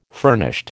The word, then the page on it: furnished: American English